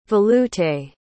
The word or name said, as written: velouté